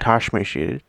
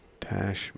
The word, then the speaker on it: tasmatiated: Tash. – Not tash. Tasmatiated. That boy has been tasmatiated. That's another thing. We should be able to put it in a sentence. – Okay. Tasmatiated.